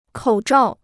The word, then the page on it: 口罩: kǒu zhào